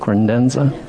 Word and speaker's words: credenza: Is that how you say it? Credenza? – Credenza.